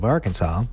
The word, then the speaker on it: Arkansas: That's right, according to Title I, Chapter 4, Section 104 of the Arkansas Code, there's only one right way to pronounce the state name, and that's Arkansas. Not Arkansas or Arkansas. Those would both be considered illegal. According to the rule, the correct pronunciation of Arkansas with the final S being silent was made official by an act of state legislature in 1881.